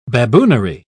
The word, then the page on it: baboonery: British English